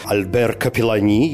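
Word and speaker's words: Albert Capellani: Режиссером выступил Альбер Капеллани, если произносить да, его именно на французский манер, поскольку ну, он все-таки французский.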